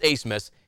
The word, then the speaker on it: Abmas: Watch out for Max Abmas. If you see his name, it is pronounced Asmus.